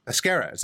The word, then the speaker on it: Pesquera: So on the line, we have our draft expert for this year, Javier Pesquera. Pesquera.